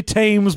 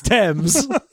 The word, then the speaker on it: Thames: Also, to be like, you can't just say Thames because it rhymes with Ames. It's Thames.